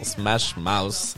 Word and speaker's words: Smash Mouth: Exactement, Smash Mouth.